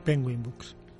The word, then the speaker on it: Penguin Books: y es un libro que fue publicado por Razorville, un sello de Pekin Books, en 2007. – Penguin Books. – Penguin Books. Siento la pronunciación, pero...